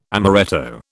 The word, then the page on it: amoretto: British English